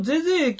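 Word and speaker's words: 膳所: はい、ちなみに、さっき言いました、まあ、当時、大津駅は、ゼゼ駅っていう、あ、はいはい、まあ、読めない字があるんですよね。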